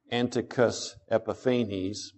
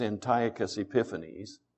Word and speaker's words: Antiochus Epiphanes: You may have pronounced that Antiochus Epiphanes, but it's Antiochus Epiphanes.